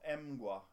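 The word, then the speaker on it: Mgła: Eh, det här är ju ett polskt band som heter Emgua. – Är det rätt uttal där nu? – Ja, jag tror att det är rätt uttal. – De men det... alla gotländska polacker efter det. Mm. – Ja, Emgua.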